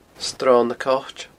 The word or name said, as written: sronnacoit